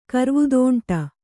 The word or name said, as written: ಕರ್ವುದೋಂಟ